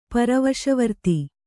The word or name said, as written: ಪರವಶವರ್ತಿ